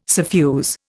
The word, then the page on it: suffuse: Indian